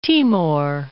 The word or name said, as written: Timor